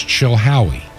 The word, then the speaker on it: Chilhowie: The city is Chilhowie. Chilhowie, Virginia. Chilhowie is spelled C-H-I-L-H-O-W-I-E. Chilhowie, Virginia.